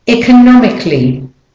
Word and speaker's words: economically: Economically. Economically. So the first O and the I are the weak syllables. All the rest, the vowels are stressed.